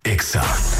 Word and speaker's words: EXA: No se dicen éxitos, Se dice EXA. – En todas partes. – En todas partes. Ponte EXA FM. 89.9. – La Caminera está de vuelta por EXA FM.